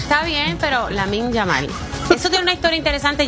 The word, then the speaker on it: Lamine Yamal: Lamine Yamal. ¿Lo dije bien, Angie? Lamine Yamal. – Sí, que sí.